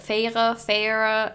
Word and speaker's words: Feyre: I'm definitely butchering her name. I don't know how you're supposed to pronounce it. Feyre. It's spelled F-E-Y-R-E.